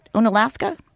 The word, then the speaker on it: Onalaska: Onalaska? – It's Onalaska.